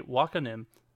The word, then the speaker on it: Wakanim: Fans will enjoy even more anime as Funimation Wakanim? I could not understand how you would pronounce that, but I believe it's Wakanim, moved to Crunchyroll starting today.